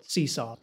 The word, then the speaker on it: CSAW: You guys call it CSAW. CSAW. – C-S-A-W. Rhymes with yeehaw.